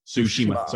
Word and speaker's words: Tsushima: I think it's I still can't say it right. Tsushima. Sorry, there we go.